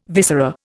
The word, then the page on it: viscera: Indian